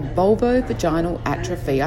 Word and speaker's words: vulvovaginal atrophy: vulvovaginal atrophy. I said that right, didn't I? – Yep, absolutely.